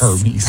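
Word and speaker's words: Hermès: What about, let's go to another luxury brand, H-E-R-M-E-S. Hermes? – Hermes. – Hermes? – Hermes? – Hermes. I can't afford them. – It's Hermes. A-I-R. – Hermes. – That's how you should pronounce it.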